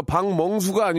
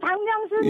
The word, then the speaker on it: 박명수: 제가 박멍수가 아니고요. – 아니, 박명수 예, 예, 예. 박멍수라고 하셨거든요.